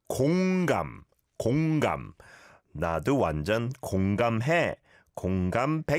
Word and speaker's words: 공감: We have to be a bit careful with the pronunciation because it kind of sounds like 건강, which means health, right? And so 공감, 공감 is today's hashtag. And it's an or vowel sound on 공, 공 like ball, and then 감, 감, 공감. 공감해.